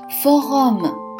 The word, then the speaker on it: forum: 那么今天我要给大家介绍一个在法国生活当中非常非常重要的单词，这个单词读作 forum。